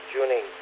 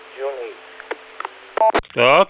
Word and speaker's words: Cuneyt: First name is spelled C-U-N-E-Y-T, but it's pronounced June 8th. Like the month June and number 8th. June 8th.